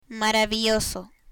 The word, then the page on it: maravilloso: Spanish